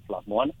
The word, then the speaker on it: Shlap Muan: We spell it S-H-L-A-P, M-U-A-N, Schlop Muon. And that's the way you say chicken wings in Khmer.